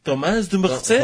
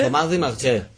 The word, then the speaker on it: Dumerchez: El responsable de esta tragedia es el amigo de aquel Frank, Thomas Dumerchez, que aquí se pronunciaría con un perfecto francés, Thomas de Merche. – Thomas de Merche.